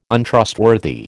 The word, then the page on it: Untrustworthy: American English